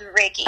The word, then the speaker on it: Reiki: Is that how you say it, Reiki? – Reiki, yeah. I thought it was Reiki. – No, it's Reiki.